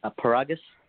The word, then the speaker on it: Paragas: Paragas.